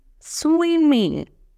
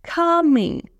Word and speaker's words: swimming: Буквосочетание -ing, вы как будто у нас с насморком, да, мы не произносим «г» на конце вот эту букву «g», да, мы говорим м, м, swimming, swimming, coming, coming, taking, taking, то есть не swimming, taking, coming, да, стараемся не русифицировать.